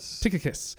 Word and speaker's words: Tychicus: Tychicus will tell you all about my activities.